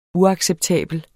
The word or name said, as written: uacceptabel